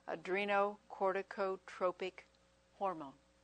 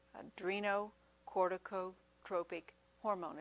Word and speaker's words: adrenocorticotropic: That's all one word. Just spell it phonetically. It's about how it's spelled. Adrenocorticotropic hormone. Adrenocorticotropic hormone.